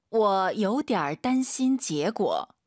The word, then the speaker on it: 我有点担心结果: 我有点担心结果. – 지금 발음 들으셨어요? 我 안 올리잖아요. 삼성이 3개 이상이면 보통 주어가 삼성일 때 반삼성으로 많이 발음해요. 워, 요디알.